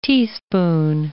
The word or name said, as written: teaspoon